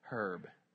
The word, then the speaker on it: herb: For they will soon fade like the grass and wither like the green herb. Herb. H is silent.